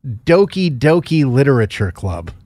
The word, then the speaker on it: Doki Doki Literature Club: So what is Doki Doki Literature Club? – You pronounced it right.